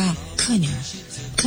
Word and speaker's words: colonel: C O L O N E L เนี่ยเราออกเสียงว่าเคนเคนนะคะก็คือลงเสียงหนักที่พยางค์แรกนะ